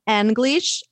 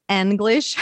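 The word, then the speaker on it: Annglish: So you can find me on Instagram at Anglish. Actually, that's a name that my students gave me, Anna English. So it's Anglish. – Can you spell it for everybody? – Yes, yeah. It's A-N-N-G-L-I-S-H.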